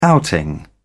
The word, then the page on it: outing: British English